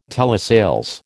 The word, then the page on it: telesales: American English